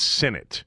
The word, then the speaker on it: Sinnott: But in the K-State pronunciation phonetic spelling, it's S-I-N-I-T, as in Sinnott, if you were wondering and curious.